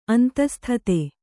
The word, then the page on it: ಅಂತಸ್ಥಿತ: antasthita